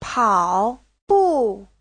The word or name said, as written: pao3bu4